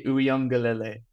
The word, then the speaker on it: Uiagalelei: I meant to send you a video on how to pronounce it. It's DJ Uiagalelei. – Uiagalelei. – Yeah. – Class. You're worth your weight in gold, mate. DJ Uiagalelei.